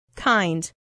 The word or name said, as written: kind